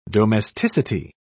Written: domesticity

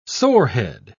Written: sorehead

